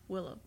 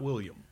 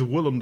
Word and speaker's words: Willem: Willem, not William. Willem. – Willem. There Willem. Is only one L. – Yeah. Willem Dafoe. – I, really? – Not William.